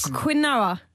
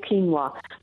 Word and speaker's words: quinoa: Quinoa. – Is that correct? – We recommend quinoa. It's a word from Spanish. And wow. – The O is like, you treat it like a W, quinoa.